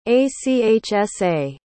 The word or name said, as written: achsa